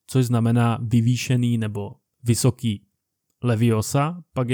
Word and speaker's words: leviosa: Dozvídáme se, že je také velmi důležitá výslovnost, protože jistý kouzelník Barufio vyslovil místo leviosa leviofa a mělo to katastrofální následky.